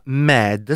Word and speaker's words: MYD: Lui si chiama Mad, si pronuncia proprio così, però è scritto MYD e però si pronuncia Mad così, con un po' di belato Mad.